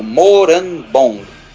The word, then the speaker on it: Morambong: É assim, se escreve assim como se fala mesmo, Morambong.